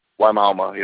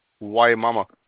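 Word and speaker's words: Wimauma: Yeah, Wymalma, yep. – How did you say that? – Wymalma. – Why mama? – Wymauma. – Why mama? All right, so just take the U out of there.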